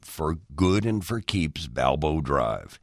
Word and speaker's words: Balbo: A few of them, even as they looked up at the street sign, said in what's become a kind of common Chicago mispronunciation, Balboa. Well, it is Balbo,